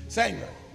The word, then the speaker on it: Sanger: Margaret Singer, do the math, do the research on Margaret Singer and see what kind of a racist she is. Sanger, spell it. S-A-N-G-E-R.